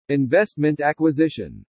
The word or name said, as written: investment acquisition